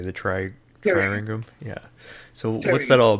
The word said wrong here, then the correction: Tyringham: Triringum, Tyringham Hall